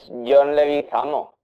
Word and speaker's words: Leguizamo: Woody Harrelson, Andy García, Matt Damon o John Leguizano. Leguizamo, no sé si pronuncio bien ese. Ah, no, es, es John Leguizamo, correcto.